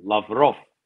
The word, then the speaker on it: Lavrov: I mean, Sergei Lavrov, Raymond Governor emphasis is on the last syllable, Lavrov.